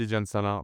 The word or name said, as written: Tidjane Salaun